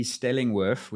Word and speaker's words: Stellingwerff: Stellingworth, which is spelled exactly like it sounds, except it's got two F's at the end. T. Stellingworth. Stelling, as you would imagine, Stelling, S-T-E-L-L-I-N-G, and then Worth, W-E-R-F-F.